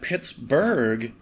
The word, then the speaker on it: Pittsburgh: And Pittsburgh is mm-hmm. spelled in the Scottish style. Yes. And it's supposed to be pronounced Burrah, the way that you pronounce Edinburgh.